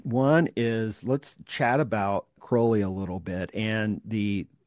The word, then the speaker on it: Crowley: Oh, Crowley. Yes, Crowley. Sorry. Rhymes with holy.